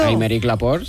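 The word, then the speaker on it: Aymeric Laporte: Améric Laporte, sí. – Ay, qué bien pronunciado. – Es francés, claro.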